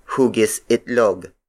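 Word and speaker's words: hugis itlog: Another Tagalog word for oval is hugisitlog. Hugisitlog. Hugisitlog. Hugisitlog is spelled as H-U-G-I-S hyphen I-T-L-O-G.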